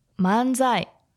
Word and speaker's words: manzai: What's the intonation for manzai? How do you say that? – Manzai. – Manzai. – Yes.